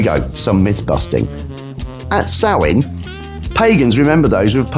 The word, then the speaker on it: Samhain: Now I'm saying this as it is first, as Samhain, S-A-M-H-A-I-N. It is not pronounced Samhain, but rather Samhain.